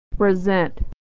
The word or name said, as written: to present